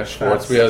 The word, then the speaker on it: Schwarzbier: Or Schwarzbier. Schwarzbier is how I pronounce it for the public.